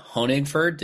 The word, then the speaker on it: Honigford: And today we have a really exciting conversation with Alyssa Honigford.